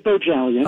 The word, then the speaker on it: Bohjalian: And in the book, The Red Lotus, we're talking with Chris Bohalian. Bohalian, and I always want to pronounce the J, Chris. Bohalian. – Well, you do, you do. It's actually, it is Bojelian.